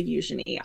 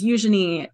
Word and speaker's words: Eugenie: And since I've started making TikToks, it's almost become a running joke on my page because Eugenie is a very British way of pronouncing it. In the French, you might say Eugenie. The general British population pronounces it as Eugenie sometimes or Eugenie.